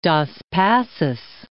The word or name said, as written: Dos Passos